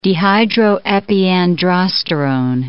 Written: dehydroepiandrosterone

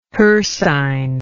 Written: hircine

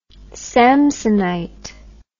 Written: Samsonite

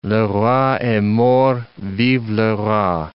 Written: le roi est mort, vive le roi

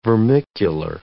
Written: vermicular

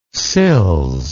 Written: sills

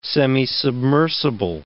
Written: semisubmersible

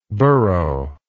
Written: burrower

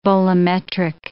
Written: bolometric

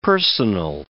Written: personal

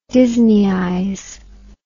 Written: disneyize

